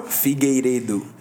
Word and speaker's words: Figueiredo: Figueiredo. – Figueiredo. – Because, R for us, like, in this Figueiredo. – Case... Re. Figueiredo. – Figueiredo. – Yeah. – Dude, the guy's a beast. – Davidson Figueiredo.